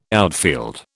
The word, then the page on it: outfield: British English